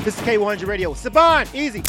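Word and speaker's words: Siobhan: Sibobhan. But it's pronounced Sivan.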